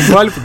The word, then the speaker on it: Valve: Мы всегда говорим Valve, говорят неправильно произносить, произнести как-нибудь по-другому. Мы сегодня даже слушали на повторе переводчик Google Translate, как произносить. Он говорит Valve.